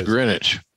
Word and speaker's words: Greenwich: Yeah, well, it's spelled Greenwich. It's Greenwich.